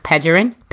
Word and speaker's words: Pegeron: So Pegoron is spelled P-E-G-E-R-O-N. And shout out to you, Jen, because you said my name the correct way. Because people, every time I go somewhere, they're like, Pegoron, Pegasus. Like, people don't read, just read. Just read. Still clap your hands. Use a syllable.